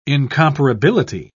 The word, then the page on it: incomparability: British English